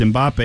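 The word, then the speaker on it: Mbappé: So it's I guess it's Mbappe, but the French say it's Mbappe, and he's French, so I'm going with that.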